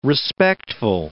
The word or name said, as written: respectful